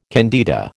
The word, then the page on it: candida: American English